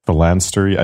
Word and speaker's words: phalanstery: Is it phalansteries? – Yeah, like monasteries, but... – Phalanstery? I think it's phalanstery.